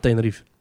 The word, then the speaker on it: Tenerife: Dus ik, ben fan, Wikipedia, ik ben fan van Tenerife. – de Wikipedia van Tenerife staat... Tenerife wordt met vier lettergrepen uitgesproken... maar Nederlandstaligen laten meestal de laatste lettergreep weg.